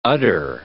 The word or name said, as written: udder